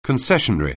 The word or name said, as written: concessionary